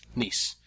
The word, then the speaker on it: Nice: Nietzsche? Nice? How do you say it? – Nice.